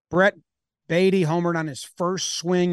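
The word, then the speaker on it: Baty: My understanding is it's Brett Beatty. Because I've seen people tweet that this should be pronounced Batty because baseball's plays yeah. play with bats.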